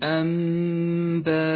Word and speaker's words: أنباء: المثال التالي على حكم الإقلاب في كلمة أنباء: تقلب النون إلى ميم فتسقط من اللفظ، وأما الميم فتحتاج إلى غنة زمنها حركتان: لونت النون بالرمادي لأنها لا تلفظ، ولونت الميم بالأخضر إشارة إلى موقع الغنة.